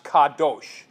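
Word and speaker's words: kadosh (קדוש): And if you notice on the sheet, on the far right, you see the word in Hebrew characters is pronounced kadosh.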